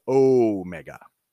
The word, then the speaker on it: Omega: But Omega is the long O sound, so it's Omega.